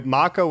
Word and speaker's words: Mako: Someone's going to say Imutai because, yeah, Mako was tough, but the Japanese pronunciation would be Mako as the way their syllabary works.